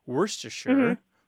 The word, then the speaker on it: Worcestershire: That word is so old, Worcestershire is how you say it, more or less.